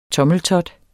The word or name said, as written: tommeltot